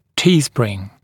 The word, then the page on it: T-spring: ‘tiːsprɪŋ; ‘ти:сприн